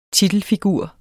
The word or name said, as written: titelfigur